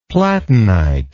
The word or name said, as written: platinite